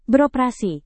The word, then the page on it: beroperasi: Indonesian